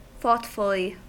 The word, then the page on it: thoughtfully: American English